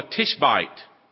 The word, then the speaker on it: Tishbite: He was called a Tishbite. Rhymes with fishbite.